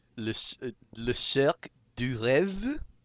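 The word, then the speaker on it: Le Cirque des Rêves: Le Cirque du Rêve? – Yeah, see, that was much better than my.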